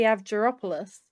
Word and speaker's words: Avgeropoulos: How do you say it? – I say Marie Avgeropoulos. – Astropolis. – Abderopolis. It's Greek.